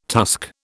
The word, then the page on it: tusk: British English